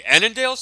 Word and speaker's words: Annandale: at the Annandale Sports Center. Am I pronouncing that correctly? – Yes, you are. Good job.